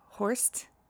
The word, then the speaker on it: Horst: I believe you say your last name Horst. It's H-O-R-S-T.